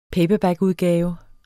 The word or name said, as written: paperbackudgave